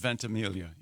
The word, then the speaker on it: Ventimiglia: Ventimiglia? – Does he go with the soft G or does he do the Mike Birbiglia thing? – I have heard the soft G. – I've heard Ventimiglia, yes.